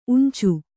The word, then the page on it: ಊಂಚು: ūñcu